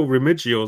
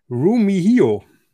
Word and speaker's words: Remigio: Remigio, R-E-M-I-G-I-O. Klingt nach einem äh, ähm, Italiener, Remigio, Re Remigio. Er wird völlig anders ausgesprochen. – Ne? – Rumihio. Nico Rumihio.